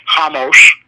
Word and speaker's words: Hamos: It's spelled Ramos, but I know it's Hamos now after doing the show.